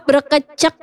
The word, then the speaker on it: berkecek: Jadi namanya itu brokocok. – Oh, berkecek ya, bacanya berkecek ya.